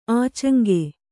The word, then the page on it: ಆಚಂಗೆ: ācaŋge